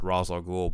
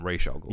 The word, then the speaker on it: Ra's al Ghul: They did an interesting thing in Arrow where if you are an outsider, you pronounce it Ra's al Ghul, but if you're initiating the League of Assassins, you call him Ra's al Ghul.